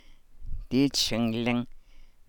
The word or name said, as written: dii tsinlang